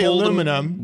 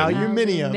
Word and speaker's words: aluminium: You guys have an extra I. – Okay. – When it's, we say aluminum. – When it's called American. – Aluminium. – Aluminium.